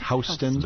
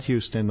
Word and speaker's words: Houston: And it's Houston. Even though it looks like Houston, it's Houston. – That's correct. – Houston. I generally have to pause and think, okay, it's not Houston, though it looks like that's how you'd pronounce it.